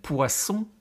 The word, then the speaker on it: poisson: Dus daarom heb je ook het verschil tussen poison, oftewel vergif, en poisson, met een scherpe S, de dubbele S. Wat is dan vis? Le poisson sans boisson est poison.